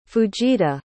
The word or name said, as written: fujita